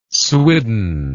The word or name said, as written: swidden